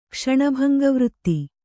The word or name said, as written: ಕ್ಷಣಭಂಗವೃತ್ತಿ